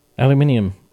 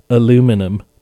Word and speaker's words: aluminium: Aluminium. Or, as Americans say, because I know otherwise someone's going to comment, aluminum.